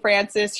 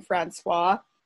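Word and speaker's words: François: except that Prince Henry should be Prince Henri, and King Francis should be King Francois.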